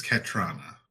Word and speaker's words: Katrana: So, Katrana? – Katrana. – Is that how that's pronounced? I think it's Katrana. – Yes, that probably sounds right.